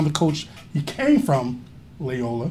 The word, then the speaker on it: Loyola: How do you say it? Loyola? – Loyola.